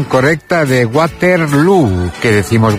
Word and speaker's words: Waterloo: Bueno, lo primero, Waterloo se pronuncia igual que Feijó.